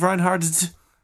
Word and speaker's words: Reinhardt: Do you pronounce the T at the end of Reinhardt's? – I just say the D really hard.